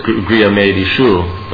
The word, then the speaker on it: grihamedishu: Because in the verse it's grihamedishu, but it's not with a long I.